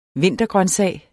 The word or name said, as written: vintergrøntsag